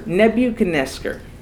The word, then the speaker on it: Nebuchadnezzar: How do you say that? – Nebuchadnezzar.